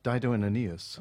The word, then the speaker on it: Dido and Aeneas: il s'agit, comme vous le savez, de la Dido and Ines, The Person. – Oh! Oh, ça fait mal prononcer comme ça. – On s'en fout, c'est pas grave. Je vais couper au moins. – Dido and Aeneas.